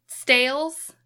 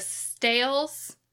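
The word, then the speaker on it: Sts'ailes: And in Canada, the Stales, I think is how it is pronounced. It's STS apostrophe AILES.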